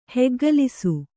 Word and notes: ಹೆಗ್ಗಲಿಸು: heggalisu

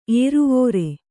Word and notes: ಏರುವೋರೆ: ēruvōre; kannada

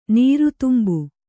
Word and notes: ನಿರ್ದಂಭ: nirdambha

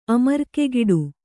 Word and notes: ಅಮರ್ಕೆಗಿಡು: kannada

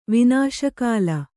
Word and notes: ವಿನಾಶಕಾಲ: Kannada

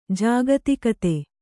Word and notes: ಜಾಗತಿಕತೆ: jāgatikate

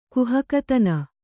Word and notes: ಕುಹಕತನ: kannada

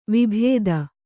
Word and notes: ವಿಭೂತಿ: vibhūti

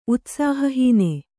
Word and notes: ಉತ್ಸಾಹಹೀನೆ: utsāhahīne

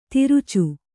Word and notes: ತಿರುಚು: tirucu; kannada

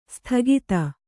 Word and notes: ಸದಾಗತಿ: Kannada

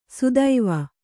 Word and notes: ಸುದೈವ: Kannada; sudaiva

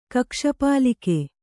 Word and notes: ಕಕ್ಷಪಾಲಿಕೆ: kannada

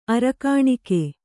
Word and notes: ಅರಕಾಣಿಕೆ: arakāṇike

